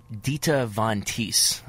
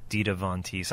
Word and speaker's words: Dita Von Teese: And I guess it's really helped him get through his recent split from Dita Von Teese, I believe, is the pronunciation on that. – Dita Von Teese.